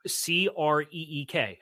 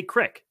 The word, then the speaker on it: creek: And like C R E E K. How do you pronounce it? Creek. It some people up here say crick. I've heard crick. That's I've heard that.